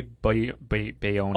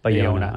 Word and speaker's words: Bayona: Bayona. – Oh, you did. Oh, it's your story. – I figured you'd know. – Yeah, sorry. – J.A. Bologna. Yeah. – Bayona, I'm sure.